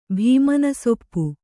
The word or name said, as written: ಭೀಮನಸೊಪ್ಪು